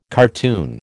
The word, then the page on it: cartoon: American English